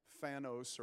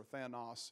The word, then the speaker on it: Thanos: So anyhow, I showed up, and the whole premise of the movie is there's an evil character named Death, Thanos, or Thanos, if you're correcting your Greek pronunciation.